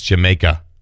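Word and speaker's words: D'yer Mak'er: Everybody mispronounces it, and that's a joke. It's an old British gag. My wife has gone to the West Indies, Jamaica. No, she went of her own accord. You say it with an English accent, and it's Jamaica, right?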